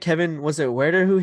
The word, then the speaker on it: Huerter: Oh, I, I, genuinely didn't know how to say it. – Herder. – Herder. Herder. Yeah.